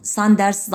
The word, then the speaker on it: Sanders: Daha önce söylemiş miydim bilmiyorum ama Sanders Sanders diye okunur Almanca'da ama Z ile yazılmaz.